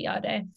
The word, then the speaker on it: Jade: Is that how you say your name, by the way? I'm sorry. Is that your Finnish name or is that your actual name? Yeah, Finnish name. When I'm in Finland, I say Jade.